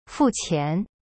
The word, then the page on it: 付钱: fu qian